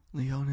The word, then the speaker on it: Lyon: Lyon is pronounced almost like lion, but it's not. It's with a Y.